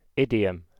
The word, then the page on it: idiom: British English